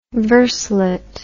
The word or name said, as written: verselet